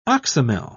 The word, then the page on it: oxymel: British English